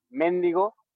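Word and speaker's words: méndigo: O sea, por ejemplo, mendigo, con acento en la primera E, como si fuese esdrújula, que es una palabra que solamente se dice en México, creo.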